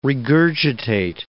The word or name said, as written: regurgitate